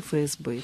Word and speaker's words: ФСБ: Дело в том, что при такого рода аббревиатурах мы должны произносить буквы так, как они называются в алфавите. И действительно, с точки зрения правильной русской речи, мы должны говорить ФРГ и ФСБ, потому что название буквы звучит как F.